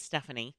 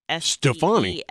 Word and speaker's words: Stefani: No, her name is Stephanie. – Right. – But it's spelled Stefani. – S-T-E-F-A-N-I. Stefani. – Stefani. Yeah.